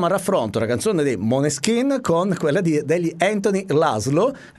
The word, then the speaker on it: Måneskin: Faccio sentire un piccolo estratto, insomma, delle due canzoni incriminate, cioè quella appunto dei Maneskin, che oggi, vedendo un'intervista, ho scoperto si pronuncia Måneskin.